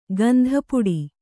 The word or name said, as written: ಗಂಧಪುಡಿ